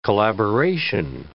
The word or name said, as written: collaboration